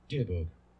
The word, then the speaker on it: Dybbuk: I think it's Dybbuk box. I'm going to look it up real quick because I hate saying that wrong. Sorry. Let me look up something real quick. Well, that didn't help me at all. – Dibbuk. Dibbuk. – Dibbuk. All right, Dibbuk box. All right, so it's a Dibbuk box.